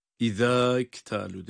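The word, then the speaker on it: izektalu (إذا اكتالوا): Onun için izektalu değil, izektalu diye medsiz bağlıyoruz.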